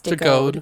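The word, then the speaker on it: DeGoede: And it also stars Selah DeGorde, or DeGode. It's D-E-G-O-E-D-E. I'm probably saying her name wrong, but I think it's DeGode. – DeGode, yeah.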